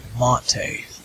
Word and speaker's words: MATE: And they pronounce it Monte, like rhymes with latte.